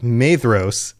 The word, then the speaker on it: Maedhros: And I just realized, I've been doing it again. Pronunciation is rough. Maedhros, it's a DH. I talked about this on the last episode, and yet I forgot.